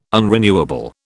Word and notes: unrenewable: British English

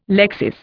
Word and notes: lexis: Indian

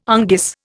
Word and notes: unguis: Indian

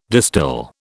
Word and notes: distil: British English

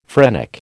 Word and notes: phrenic: American English

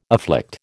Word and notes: afflict: American English

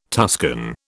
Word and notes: Tuscan: British English

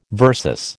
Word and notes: VS: American English

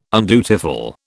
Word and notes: undutiful: British English

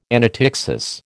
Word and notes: anaptyxis: American English

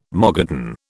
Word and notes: Mogadon: British English

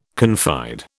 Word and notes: confide: British English

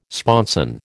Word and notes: sponson: American English

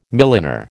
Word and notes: milliner: American English

